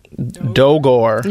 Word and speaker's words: Dogor: D-O-G-O-R. – Dogor.